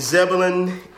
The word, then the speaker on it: Zebulon: Born and raised in Zebulon, NC, North Carolina, bro.